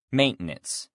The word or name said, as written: maintenance